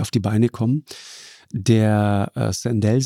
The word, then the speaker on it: Sandel: Sandel, spricht man den aus, ja.